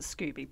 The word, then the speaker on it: SCOBY: So we probably should say that I pronounce it Scooby, but I am Australian.